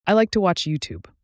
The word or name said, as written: youtube